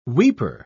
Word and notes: weeper: British English

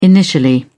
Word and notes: initially: British English

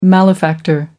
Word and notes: malefactor: American English

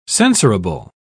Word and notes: censorable: British English

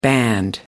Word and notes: band: American English

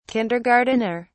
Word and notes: kindergartener: British English

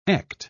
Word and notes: echt: British English

